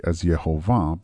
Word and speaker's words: Yehovah: As a side note, I pronounce the name Yud-Heh-Vav-Heh as Yehovah because that is the most common way of pronouncing this name, if it is even mentioned at all.